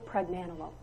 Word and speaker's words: pregnenolone: It's called pregnenolone, so I'm going to spell it for you. PREGNENOLONE. Pregnenolone. Has anybody in this room, raise your hand if you've heard of that hormone before.